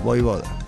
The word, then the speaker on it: Vojvoda: Mira, yo estoy con el tema de Vojvoda, ¿viste el DT de Racing? – Vojvoda. – Vojvoda. – La JB corta. – Vojvoda. – Se hace como una I, Vojvoda.